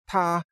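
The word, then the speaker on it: ta (他): They use an article or a pronoun that's pronounced ta, and you say, ta kai ce, means he's driving his car, or she is driving her car.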